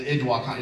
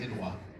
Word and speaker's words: IDW: So when I read the comments, it's the Idwa. A lot of people say IDW. It's actually pronounced Idwa.